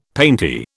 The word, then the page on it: painty: British English